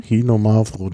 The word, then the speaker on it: Xinomavro: Mavro, ist das richtig ausgesprochen? – Xinomavro, das Xino.